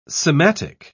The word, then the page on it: sematic: British English